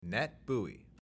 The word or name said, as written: NETBEUI